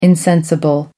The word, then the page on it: insensible: American English